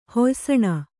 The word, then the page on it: ಹೊಯ್ಸಣ: kannada